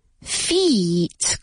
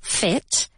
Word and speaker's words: feet: Pies. Feet. Con dos. O sea, como si fuera una I larga. Como si la I tuviese dos sílabas. Feet. Remember, one foot, two feet. Porque si lo digo con una I corta, fit, es, pues, o estar en forma, okay, to be fit, o también, pues que algo entra en algún sitio.